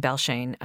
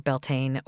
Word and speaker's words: Beltane: This one in Belshane um, or Beltane or Belshan also has a lot of different pronunciations, but this is one of the ones that she also is really drawn to.